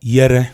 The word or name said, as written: Yere